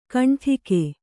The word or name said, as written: ಘುಂಟಕ